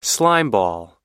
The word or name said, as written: slimeball